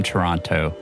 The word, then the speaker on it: Toronto: And to my knowledge, when you're from Toronto, you don't pronounce the second T. It's Toronto.